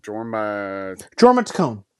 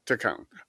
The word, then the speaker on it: Jorma Taccone: Jorma Taccone. – Taccone.